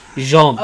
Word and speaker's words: Jean: That's spelt like Gene. It's, it's pronounced Jean. – Uh, Jean.